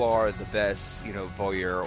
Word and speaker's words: bowyer: A bowyer? – A bowyer is how we call them? – And I believe that's how you pronounce it. – Yes. – Bower.